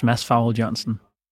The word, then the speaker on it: Mads Farholt Jørgensen: My name is Mads Farholt Jørgensen. – That's great, because pronouncing foreign names in English is very difficult, so I'm glad you did it for me.